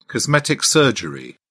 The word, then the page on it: cosmetic surgery: British English